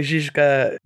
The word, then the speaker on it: Žižka: You'll be able to say Žižka, which very good pronunciation there, Matt.